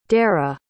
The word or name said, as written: dareh